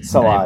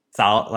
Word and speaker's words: Salah: Salad, like, just like saying salad weird. Fair enough. – It's not actually how you say it. It's not how you say it. I always hear it as Salah.